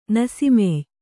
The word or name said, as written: ನಸಿಮೆಯ್